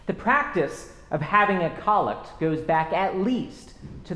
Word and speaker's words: collect: The collect is the prayer that we use at the opening of worship, and it is pronounced collect, not collect.